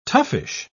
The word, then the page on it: toughish: British English